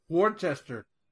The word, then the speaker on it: Worcester: Worcester. – Okay. There's no H. – Wart, that's how you say it.